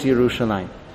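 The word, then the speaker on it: Yerushalayim (ירושלים): Whereas the pronunciation was always Yerushalayim. That's our tradition.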